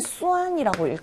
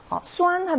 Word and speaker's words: 酸: 요 한자 어떻게 읽나요? 예, 요 한자는 수안이라고 읽거든요. 수안 하면 시다라는 뜻입니다.